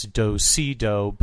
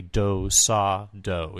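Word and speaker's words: do-si-do: Some callers would refer to this as do si do, but it's actually do sa do is how it's pronounced.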